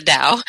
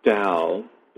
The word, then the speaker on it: Tao: The term Tao, which the ancient Chinese or modern Chinese pronounce with a D sound, it simply means the path.